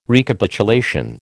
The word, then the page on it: Recapitulation: American English